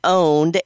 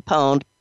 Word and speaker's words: Pwned: Troy Hunt, who runs the Have I Been Owned, and it's not actually owned, it's Have I Been Pwned, P-W-N-E-D site, and people pronounce that different ways.